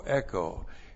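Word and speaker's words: echo (ἔχω): It looks like this. E-C-H long O, echo.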